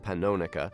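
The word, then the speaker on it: Pannonica: That's the Microscopic Septet, and a Thelonious Monk tune, which I always called Panonica. They say it's pronounced Panonica.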